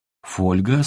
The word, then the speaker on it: фольга: Скажем, сегодня даже от людей, занимающихся русским языком профессионально, не услышишь слово «фольга» с ударением на первом слоге. Что еще за фольга такая? Только фольга. Загляните в словарь. Вы будете удивлены. Правильный, первый вариант.